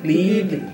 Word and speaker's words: Ghibli: O estúdio Ghibli não. Apesar do. É. – Ghibli.